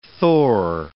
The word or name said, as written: Thor